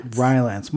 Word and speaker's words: Rylance: Mark Rylance? – Rylance.